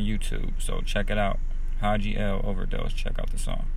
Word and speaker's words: Hadji L: But with that just being said, though, I want y'all to check out my brother, Haji L. His name is spelled H-A-D-J-I-L, Haji L, on YouTube.